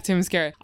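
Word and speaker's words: Themyscira: Ready, how do you say the name of the island? – Oh, you're talking about Temescara? – Yeah, Temescara.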